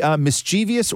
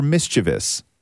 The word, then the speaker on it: mischievous: Like, there's some words that we all mispronounce deliberately, and you will never say, mischievous or mischievous? – I would say mischievous. – That's wrong. – Wait, why? – It's a completely different word. – Mischievous? – There's not that many syllables in it.